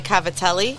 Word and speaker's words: cavatelli: Cavatelles. – Cavatelles. How do you say? I say cavatelles. You say? – Okay, so an American person would say cavatelli.